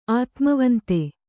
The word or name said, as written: ಆತ್ಮವಂತೆ